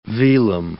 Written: velum

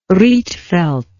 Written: rietveld

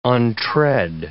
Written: untread

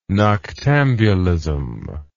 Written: noctambulism

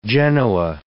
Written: Genoa

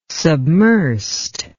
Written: submersed

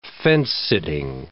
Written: fence-sitting

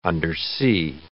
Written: undersea

